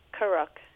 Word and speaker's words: Karuk: Now, the indigenous communities you were talking about, and I'm going to mispronounce this, and I apologize, the Karuk? – Karuk.